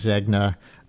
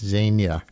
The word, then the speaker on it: Zegna: I was reading it phonetically as Zegna. Uh, I'm told by people much more familiar with uh, runways, I guess, than I am, that it's Zegna.